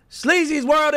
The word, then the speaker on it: Sleazy World: But we got Sleazy's World in here. Let's go. I said it right, right? – Yeah, yeah.